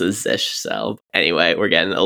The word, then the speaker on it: zsh: I say Z-S-H. You say Zish? – I've heard other people say Zish. Maybe Z-S-H is another one. Because it's like Z-S-H has three syllables. Zish, one syllable. He's the most elegant to me. – Okay, I like it. It's closest. – So I think it's like fish.